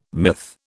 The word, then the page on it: myth: British English